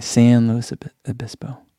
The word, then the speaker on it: San Luis Obispo: Martha says San as in San Diego. Okay, San Luis Obispo.